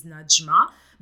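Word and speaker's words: Nadjmat: So my name is Najma, but it's spelled in a way that all the letters are not pronounced. It's N-A-D-J-M-A-T. So the D is silent in a way, and the T as at the end is silent.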